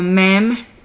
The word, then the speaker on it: mem: Okay, so the next two letters are mem and nun. Mem, it's the 13th letter of the Hebrew alphabet, and it's very simple to pronounce it. It's like M in English.